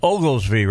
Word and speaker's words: Oglesby: And Michelle, it's Oglesby, right? – Yes. – Okay, I wanted to make sure I pronounced it right.